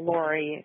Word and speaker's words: Laurie: I spell Lori, my birth name, L-A-U-R-I-E.